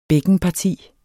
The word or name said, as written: bækkenparti